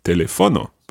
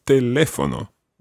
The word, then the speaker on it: teléfono: So if there was no accent on teléfono, it would be teléfono, but that's wrong. So it's teléfono.